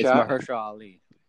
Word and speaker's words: Mahershala Ali: It's Mahershala Ali.